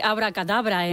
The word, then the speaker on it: abracadabra: ¿cómo se dice abracadabra en francés? – Yo creo que abracadabra. – Abracadabra. Con el acento al final.